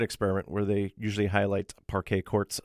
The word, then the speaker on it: Parquet Courts: But this one, just assume parquet, as I try to pronounce it phonetically, as in the type of wooden flooring that you would see at a basketball court. And then quartz, as in the court, I said.